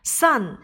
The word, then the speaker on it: son: Son 儿子，一定要注意这个音标和我们的太阳 sun 它们的发音是一样的，但是拼写有所不同。